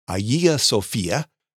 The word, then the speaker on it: Hagia Sophia: He sponsored the building of the Hagia Sophia, or the Greek pronunciation Hagia Sophia, which is one of the most stunning church buildings ever created.